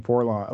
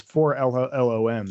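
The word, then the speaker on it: 4-LOM: And then I finally actually pronounced it instead of saying Forlom. Like, I was like, 4LOM, but no, it's 4LOM.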